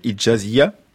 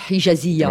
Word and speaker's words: Hijaziyya: Comment prononce-t-on, Anne-Laure Dupont ? Je ne vais pas y arriver. Hijaziyya. – La Rihla Hijaziyya, voilà.